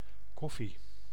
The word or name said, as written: café